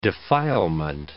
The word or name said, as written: defilement